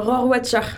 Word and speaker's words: Rohrwacher: En attendant euh, ces beaux rendez-vous, toujours à l'affiche La Chimère d'Alice euh, Rockwacher, comment tu prononces Merci, tout ton Rohrwacher.